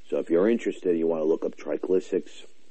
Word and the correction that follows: tricyclics: tri, tri, I'm probably not even pronouncing it right. Put you on pause let's just call them cyclics, tricyclics.